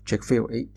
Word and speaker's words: Chick-fil-A: 那美国的这个主持人就很高兴的告诉他这个应该怎么读啊，大概就是呃 Chick-fil-A 这样的一个读法。这是前面的前言了啊。我们上一期提到呢，卓越服务的。问题，那么这个 Chick-fil-A